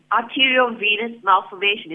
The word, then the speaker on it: arteriovenous malformation: Okay, if I can just clarify, it's an arteriovenous malformation. Is that what... – Yes. Sorry, my pronunciation was way off, but yes, AVM, he says.